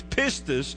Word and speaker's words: pistis: It's the little word spelled P-I-S-T-I-S, pronounced pistis.